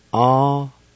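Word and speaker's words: awinutsa: A-wee-nu-za.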